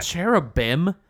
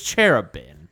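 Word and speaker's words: Chérubin: Cherubim? No, it's cherubin.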